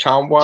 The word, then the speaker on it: Tchamwa: yeah, that Jonathan, is Tchamwa. John, yeah. Tchamwa. – Yeah, that's I think you got it right. Um, kind of like Oscar the Silent T, but yeah.